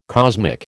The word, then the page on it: cosmic: American English